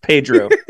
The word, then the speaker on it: Pedro: Padre, can you hear me? – All right. – It's pronounced Pedro. – Pedro. Oh.